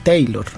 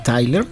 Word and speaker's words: Tyler: Sin embargo, como ella es galesa, entonces mucha pronunciación era Bonnie Tyler. Entonces mucha gente le dijo Bonnie Taylor. – Ajá. – Pero en realidad Bonnie Tyler es una de las voces más representativas.